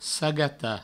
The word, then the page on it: sagatar: sagata